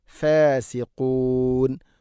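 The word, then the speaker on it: ഫാസിഖൂൻ (فاسقون): ഹുമുൽ ഫാസിഖൂൻ ഹുമുൽ ഫാസിഖൂൻ ഖാഫിൻ്റെ ഉച്ചാരണവും ശ്രദ്ധിക്കുക, ഫാസിഖൂൻ ആവരുത് ഫാസിഖൂൻ.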